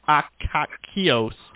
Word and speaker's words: Akakios: A-K-A-K-I-O-S. A-K-A-K-I-O-S. Meanings, origin, and history of the name Akios. Akikakos. Akikos? Kakakokoko. – I struggled with how to pronounce this, and the closest thing I could even come up with was Akakios. That was the closest thing I could even relatively come up with.